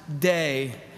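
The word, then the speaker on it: day (δέ): In the Greek, it is only two letters long, and it's made up of the letter delta and epsilon, and in the Greek, it is pronounced day, and we translate it in English to the word but.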